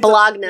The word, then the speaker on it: Bologna: Is it spelled Baloney or Bologna? I can't can't remember. – It's Bologna. A, yeah, So it's Bologna.